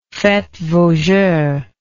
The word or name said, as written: faites vos jeux